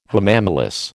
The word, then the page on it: hamamelis: American English